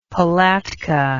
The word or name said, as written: palatka